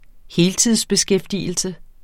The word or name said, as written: heltidsbeskæftigelse